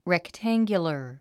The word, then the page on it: rectangular: rektǽŋɡjulər; レクタンギュラァ